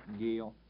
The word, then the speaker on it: Gale: Shockey is S-H-O-C-K-E-Y. – Gail Hubblesrud, G-A-L-E. Last name is H-O-V-E-L-S-R-U-D.